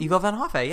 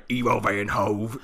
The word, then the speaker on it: Ivo van Hove: How do you say his name? – Ivo van Hoffe, yeah. How have you been saying Ivo van Hoff? – I'm so fucking from Pennsylvania. I've been like Ivo van Hove.